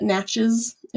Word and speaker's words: Natchez: I'm probably butchering that a little bit. Natchez. It's N-A-T-C-H-E-Z. Natchez. – Natchez? That sounds right to me.